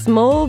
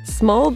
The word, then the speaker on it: Smol: Small Bean Snark, which I feel like I have to pronounce it that way, Ben, because it's spelled S-M-O-L Bean Snark. Small Bean Snark definitely falls into this latter category of anti-fan clubs.